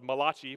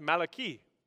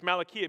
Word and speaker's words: Malachi: Malachi, or it looks like it's spelled Malachi, but it's actually pronounced Malachi. In the Hebrew, it's Malachi.